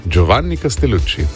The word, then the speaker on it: Giovanni Castellucci: Čestitke za izgovorjavo. Giovanni Castellucci. Bravo. Lepo.